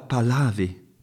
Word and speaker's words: Pahlavi: Sì, sì, mi chiamo Reza Pahlavi, capito? Pahlavi, con la H. Ok, ok, risposi io, non ti scaldare, con la H, va bene.